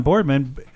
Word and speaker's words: Boardman: Shut down Boardman. Did I say that right? – Yeah. – Shut down Boardman, but it's going to be rolling brownouts.